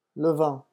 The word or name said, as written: le vent